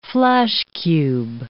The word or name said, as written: flashcube